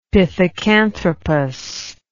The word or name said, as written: pithecanthropus